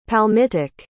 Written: palmitic

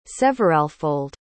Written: severalfold

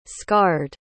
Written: scarred

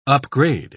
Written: upgrade

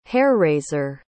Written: hair-raiser

